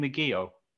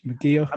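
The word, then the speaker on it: McGeouch: McGeoch, I'm going to say. It's M small c G E O U C H. McGeoch.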